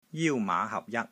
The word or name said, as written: JIU1 MAA5 HAP6 JAT1